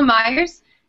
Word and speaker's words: Maiers: Yeah, Angela Myers. It's at Angela, A-N-G-E-L-A, Myers, M-A-I-E-R-S, all one word.